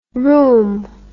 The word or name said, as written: rohm